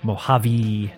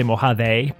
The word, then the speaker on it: Mojave: Mojave. It's a long E apparently. I always want to say Mojave, but that's wrong.